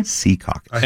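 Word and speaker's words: Secaucus: I said Sea Caucus, and they actually correct me. It's okay. Sea Caucus. – Emphasis on the sea.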